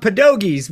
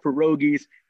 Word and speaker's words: pierogi: I do know what it, well, we call them pierogies. We pronounce the R as a D, but come yes, I know exactly what they are.